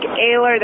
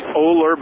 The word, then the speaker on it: Oehler: If you want to look it up, it's O-E-H-L-E-R. – Yeah, you'd think it was pronounced Oler, but it's actually pronounced Ehler.